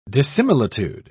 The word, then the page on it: dissimilitude: British English